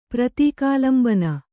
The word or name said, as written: ಪ್ರತೀಕಾಲಂಬನ